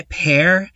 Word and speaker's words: Peaer: What I need to say about Sick by Pear is that Pear is spelled, well, it's pear like the fruit, but it's spelled P-E-A-E-R.